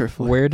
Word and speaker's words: Triflix: I mean, it's like Netflix. It's like Triflix is one of the most common ones, and I'm like, Triflix.